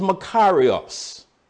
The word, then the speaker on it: makarios: The word blessed, or if you prefer the two-syllable pronunciation, blessed, in the Greek is makarios, M-A-K-A-R-I-O-S, makarios.